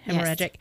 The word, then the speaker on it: hemorrhagic: Hemorrhagic, yes.